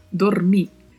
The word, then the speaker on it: dormir: Quanto à pronúncia de dormir, o O é sempre fechado e o R com vibrato, dormir.